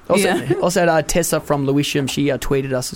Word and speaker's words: Lewisham: It's Lewisham.